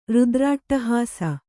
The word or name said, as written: ರುದ್ರಾಟ್ಟಹಾಸ